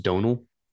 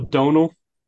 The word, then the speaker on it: Domhnall: It's pronounced Donal. – Oh, it is. Okay. See, well, there you go. I told you I was going to need some help with some pronunciations. So, Donal. – Yeah, Donal.